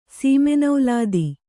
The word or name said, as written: ಸೀಮೆನೌಲಾದಿ